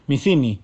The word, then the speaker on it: Metheny: Me miró muy serio, y me dijo, Messini y ahí me di cuenta de mi error en la pronunciación del apellido.